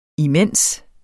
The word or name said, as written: imens